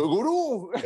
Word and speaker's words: gurú: Con acento en la segunda U. Uh. El gurú.